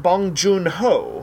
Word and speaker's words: Bong Joon-ho: I'm not sure if I'm getting around to it, but Parasite by Bong Joon-ho? – Bong Joon-ho.